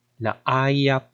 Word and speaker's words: laaya: So, in Binisaya, we say, laaya pud uy. Laaya pud uy. So, how do you spell it? It's spelled as L-A-A-Y-A,